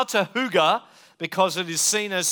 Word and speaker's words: hygge: It's spelled H-Y-G-G-E. So, and when you move to the Northern Hemisphere, that becomes hygge, hygge, hygge, if you're Danish.